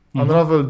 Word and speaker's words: Unravel: Unravel mhm platformóweczka słodziutka.